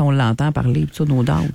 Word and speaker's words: No Doubt: Ben, je sais que je le prononce mal, Daub. Là. Je sais pas si je le prononce bien ou je le prononce mal. C'est No Doubt. Mais ça, on a toujours dit No Doubt.